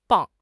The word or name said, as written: 磅